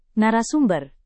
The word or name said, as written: narasumber